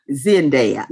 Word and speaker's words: Zendaya: Now I love Zendaya too, even though Zendaya. – She wasn't in it. How you say it? – Zendaya.